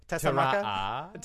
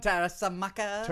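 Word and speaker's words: Tessmacher: There was an extra syllable in there when you said it. – Tara'a. – No, Tessamaka. – Tarasamaka.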